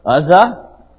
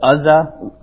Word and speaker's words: Uzzah: Do you remember Uzzah? I think that's how you say it. Uzzah, U-Z-Z-A-H, 2 Samuel.